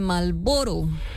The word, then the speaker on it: Marlboro: Más ignorada que la R de Marlboro. – Oye, sí, es que una R es que nunca suena. – No se pronuncia.